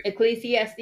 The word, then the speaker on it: Ecclesiastes: How do you say it? – Yep, that's how you say it. Right. Ecclesiastes.